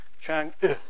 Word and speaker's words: Chang'e: And I've never worked out whether that's because it's not Chang'e, but the French pronounce this letter, and believe it or not, the Chinese pronounce it that way too, Chang'e.